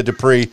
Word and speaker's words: Dupriez: Now, I have been listening all day long to the India Dupriez, and I hope I'm pronouncing the last name properly. Say the last name one more time. – Dupriez. – That's it.